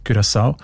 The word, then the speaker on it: Curaçao: Spricht man eben mit «ü» aus, weil Holländisch dort auch eine wichtige Sprache ist. Und im Holländischen ist das «u» offenbar häufig als «ü», Curaçao.